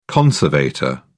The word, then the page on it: conservator: British English